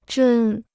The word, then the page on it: 针: zhēn